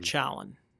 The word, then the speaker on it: Challen: Challen. Yep. Like challenge, I always put. Yep. In all my emails.